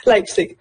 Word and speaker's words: Leipzig: Leipzig.